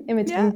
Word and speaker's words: Imogen: Imogen.